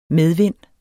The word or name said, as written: medvind